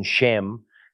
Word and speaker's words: shem: El mover el pequeño punto localizado encima del carácter a la derecha del carácter del abecedario hebreo lo hace un shem que nos da un sonido esh, como Sharon. El mover el pequeño punto a la izquierda hace el mismo carácter de sem, dándonos el sonido de la S, pronunciado como sam.